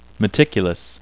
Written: meticulous

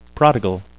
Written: prodigal

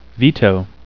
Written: veto

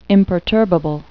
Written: imperturbable

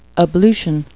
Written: ablution

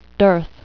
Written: dearth

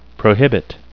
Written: prohibit

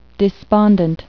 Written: despondent

